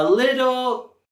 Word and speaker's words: little: Or podemos contestar, it's a little... – It's a little spicy. – It's a little sweet. – Y podemos vincular juntos, it's a. En la palabra little, tenemos nuestro sonido flat T otra vez.